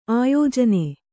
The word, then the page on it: ಆಯೋಜನ: āyōjana